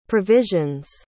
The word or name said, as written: provisions